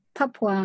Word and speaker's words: Papua: Papua.